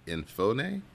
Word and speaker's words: Enofe: E-N-O-F-E? Enofe? – Enofe. – Enofe. Enofe. Okay,